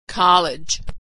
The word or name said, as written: college